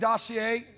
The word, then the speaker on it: dossier: Because when they tried to say this fake dossier, is that how they pronounce it? Yes.